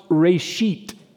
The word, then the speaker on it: reshit: It's pronounced Reshit. I promise you.